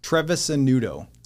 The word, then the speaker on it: Trevisanutto: Trevisanutto. – Trevisanutto. – Yeah. So it's just like the way it looks. You just break it into like small syllables. Mm. – It's like Travis Amaretto.